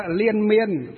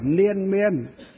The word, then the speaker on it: liên miên: Đây, nờ, nờ ngắn thì đọc là liên là gì nữa? Ốm đau liên miên. Chứ L dài N, L dài chứ không phải là N, chứ liên miên chứ không phải là liên miên.